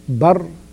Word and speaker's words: البر: And this name was mentioned in the Quran once, and the word, as we usually start by talking about the linguistic meaning, the word Barr could be pronounced three ways: Barr, Burr, and Birr.